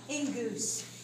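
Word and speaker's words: engous (ἐγγύς): The word near in Greek is engous. It looks like egos, doesn't it? But it's pronounced like engous.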